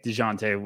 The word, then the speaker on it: Dejounte: Trae Young and Deontay, Dejounte, what Spurs fans always yell at me for my pronunciation.